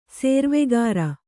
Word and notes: ಸೇರ್ವೆಗಾರ: Kannada

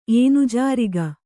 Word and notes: ಏನುಜಾರಿಗ: ēnujāriga

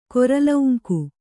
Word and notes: ಗುರುಲಿಂಗ: guru liŋga

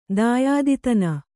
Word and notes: ದಾಯಾದಿತನ: dāyāditana

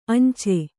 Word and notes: ಅಂಚೆ: kannada

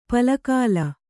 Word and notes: ಪಲಕಾಲ: pala kāla

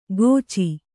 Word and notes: ಗೋಚಿ: kannada

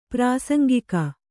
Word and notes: ಪ್ರಾಸಂಗಿಕ: prāsaŋgika; Kannada

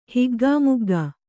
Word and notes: ಹಿಗ್ಗಾಮುಗ್ಗಾ: higgāmuggā

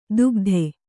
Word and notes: ದುಗದೆ: dugade

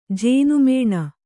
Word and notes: ಜೇನುಮನೆ: jēnu mane

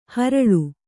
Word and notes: ಹರಳೆ: kannada

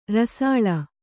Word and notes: ರಸಾಲ: Kannada

